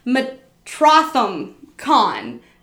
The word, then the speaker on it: Metrotham Con: I always say the name wrong. Metrotham Con. It's like Metropolis and Gotham, but one word.